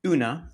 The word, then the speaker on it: EUNA: He has now led two GovTech companies as CEO, and today runs UNA, if I'm pronouncing this correctly, E-U-N-A, for those who are listening out there.